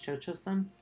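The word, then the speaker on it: Cio-Cio-San: It, you know, because, you know, I think it's pronounced Cho-Cho-Sun.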